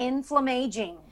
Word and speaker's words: inflammaging: The anti-inflammaging, flammaging? – Oh. – How do you say it? – Inflammaging. – Inflammaging.